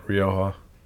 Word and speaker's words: Rioja: Front up, that wine character, whatever you said that it was, Rojas or whatever. – Right, Rioja.